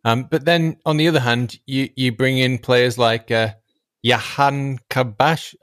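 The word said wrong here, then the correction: Jahanbakhsh: I'm saying that wrong, aren't I? – Ali Reza Jahanbach. – Jahanbach, there you go.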